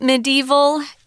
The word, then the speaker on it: medieval: This word medieval has about five different pronunciations. So, for the most part, it's pronounced medieval or me medieval with a long e sound. Um, I'm like Tom. I pronounce it with the more of the the, sh the short i medieval, but they're all correct. So pick the one you like. There are even some people that say medieval, which mm. I just think is too too crazy, too many syllables.